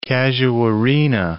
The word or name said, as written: casuarina